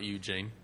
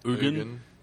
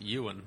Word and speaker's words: Ugin: It's not Eugene. Eugen? – Ugin. – You were saying that it's a quiet G, which just makes it Ewan, like Ewan McGregor. Ewan. – I think it's Ugin.